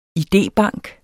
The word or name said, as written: idébank